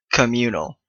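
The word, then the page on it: communal: Canadian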